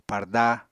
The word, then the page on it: parda: Apatani